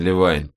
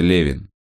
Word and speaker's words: Левин: И его фамилия произносится Левин». – «Да, я понял, как Кевин.